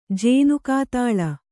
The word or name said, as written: ಜೇನುಕಾತಾಳ